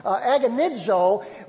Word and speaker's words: agonizo: And the Greek word that He uses there is a word that is pronounced agonizo. We get our word agonize from it.